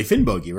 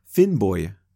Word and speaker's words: Finnbogi: We are not pronouncing Finnbogi according to modern Icelandic phonology. Now, if I heard it right on the YouTube video I watched, the name is actually pronounced something like Finnboy.